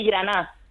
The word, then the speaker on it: Graná: Voy a decir Graná. – Muy bien dicho. Eso le gusta. – Muy bien dicho. – Graná es sin D, ¿no? Es Graná. – Graná con acento. Vale. Graná. Lleva tilde.